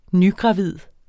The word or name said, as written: nygravid